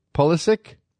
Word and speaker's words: Pulisic: The 17-year-old Borussia Dortmund midfielder Christian Pulisic, who we heard on the broadcast, wants his name pronounced Pulisic? Pulisic? Made his U.S. men's national team senior level debut, which cap ties him to the United States, so everybody can exhale on the possibility of Christian Pulisic, who pronounces his name that way because he's American, by the way, will not be playing for Croatia.